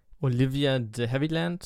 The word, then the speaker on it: Olivia de Havilland: Letzte Nachricht von mir noch Olivia de Havilland, so wird sie ausgesprochen, ist mit 104 Jahren verstorben, was ein sehr, sehr hohes Alter ist.